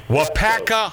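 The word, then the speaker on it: Waupaca: Now, is it pronounced Wapaka? – Wapaka High School in Wapaka, Wisconsin.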